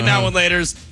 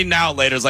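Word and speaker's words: Now and Later: I say now and laters. You say now laters like it's all one word.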